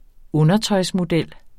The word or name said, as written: undertøjsmodel